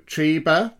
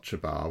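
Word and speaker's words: Trebah: So We went to Triba, which I've now learned is the correct pronunciation, not Tribar, which is what I tend to call it. It is Triba.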